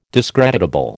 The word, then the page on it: discreditable: American English